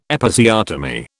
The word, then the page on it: Episiotomy: British English